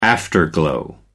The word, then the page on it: afterglow: American English